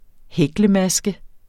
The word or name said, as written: hæklemaske